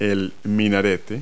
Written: minaret

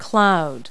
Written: cloud

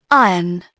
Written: iron

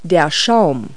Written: foam

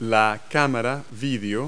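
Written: video camera

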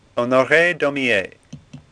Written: Daumier

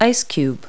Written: ice cube

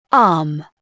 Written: arm

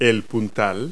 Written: strut

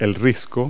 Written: cliff

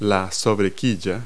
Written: keelson

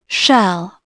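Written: shell

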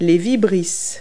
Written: whiskers

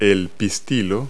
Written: pistil

